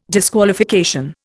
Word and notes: disqualification: Indian

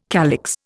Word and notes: calyx: Indian

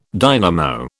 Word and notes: dynamo: British English

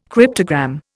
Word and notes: cryptogram: Indian

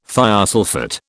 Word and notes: thiosulphate: British English

